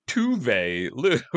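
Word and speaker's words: Tove Lo: Like, I'm like, all right, Rue, let's soften that up a little bit, because it's Swedish, it's not German. Like, look, it's a little softer on the pronunciation. But Tuve Lou,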